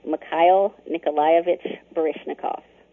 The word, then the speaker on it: Mikhail Nikolaevich Baryshnikov: His name is pronounced Mikhail Nikolaevich Baryshnikov.